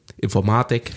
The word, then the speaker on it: Informatik: Und in der ersten Vorlesung sagt der Professor, naja, im Deutschen ist ja die Betonung aufs Zweite, Informatik.